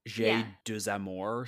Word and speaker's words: J'ai deux amours: How would you pronounce I Have Two Loves? – J'ai deux amours, or something like that. – That's it. That's right.